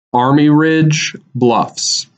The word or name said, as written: Army Ridge Bluffs